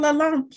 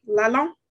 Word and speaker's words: Lalonde: My guest today is Paul Lalonde. Paul, how are you doing? Did I say that right? Lalonde? – I think you put a little French spin on it, which I admire.